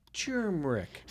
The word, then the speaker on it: turmeric: Rob says it's subtle. It's turmeric. – Turmeric. – You're supposed to mumble it. – Mumble it. – Yeah, it's like Rochesterians say Rochester in like a syllable and a half.